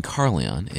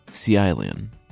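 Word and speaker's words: Caerleon: The city I've been calling Carleon is, according to a listener, pronounced Cialion.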